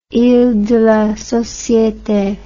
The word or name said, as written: iles de la societe